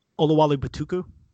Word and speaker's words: Oluwole Betiku: Um, the guy whose name I can never pronounce, when I when I go back in this Ola, Batiko, is it Olawali Batiko the Edge? Oluwole Betiku. Betiku.